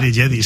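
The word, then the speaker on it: Djedi: Con Jedi, lo digo bien ya, ¿verdad? – Jedi, sí.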